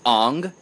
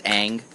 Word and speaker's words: Aang: Night Shyamalan It was Ong, which obviously is the right way to pronounce it, in spite of the TV show, which was American-made, pronounced Ang over and over again. Apparently, it's still supposed to be pronounced Ong.